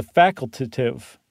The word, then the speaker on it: facultative: The ones that you can't count on at all are the facultative migrants. And I said it right. I didn't just add an extra syllable.